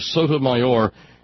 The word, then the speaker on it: Sotomayor: Putting the emphasis on the final syllable of Sotomayor is unnatural in English, which is why the president stopped doing it after the first time at his press conference.